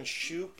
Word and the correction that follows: Schoop: Scope. It's pronounced.